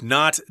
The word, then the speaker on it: knot: Be sure to ask about knot density, as more knots indicate better quality and a smoother texture. So here we've got the word knot, K-N-O-T. It's pronounced the same as knot.